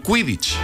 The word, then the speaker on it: Quidditch: A ver si lo digo bien, ¿eh, Alejandra? El Quidditch. – Perfecto.